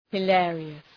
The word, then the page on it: hilarious: hı’leərıəs